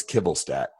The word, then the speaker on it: Kivelstadt: It's Kivelstadt. You know what's funny? It's phonetic. It reads exactly as it looks.